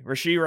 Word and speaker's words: Rashee: Rashee Rice.